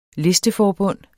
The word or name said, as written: listeforbund